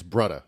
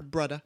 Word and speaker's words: brudda: In Hawaii, it's Brudda, not brother. – Brudda. – that's how they say it.